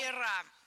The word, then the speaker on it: веер: Возможно, тебе будет интересно узнать, где правильно ставить ударение в слове «веер» в множественное число. Как ты думаешь? – Ну, на последнюю букву. Сейчас все будет плохо. Нет, угадал? – Да, веера. Веера, лорнеты, трости.